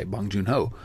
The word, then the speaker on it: Bong Joon-ho: Okay, Bong Joon-ho.